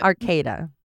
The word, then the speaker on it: Arcata: Arcata? – A-R-C-A-T-A.